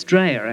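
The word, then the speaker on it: Dreja: I pronounced Chris's name wrong, I'm sure. Jimmy, tell us all about it. How wrong did I get it, in fact? – It's Dreher, actually. Polish.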